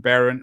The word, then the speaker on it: Behrendt: Her name is Beth Behrendt, and as she joked with me beforehand, Behrendt rhymes with parent, and she's joining us from Fort Wayne, Indiana.